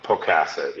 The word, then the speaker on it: Pocasset: Is it Pocasset? – Pocasset, yeah.